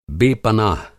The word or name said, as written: बेपनाह